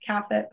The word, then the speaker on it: Caffet: So it's at Brittany May Caffet. My last name is spelled really weird. C-A-F-F-E-T. Everyone thinks it's pronounced Caffet, but it's Caffet.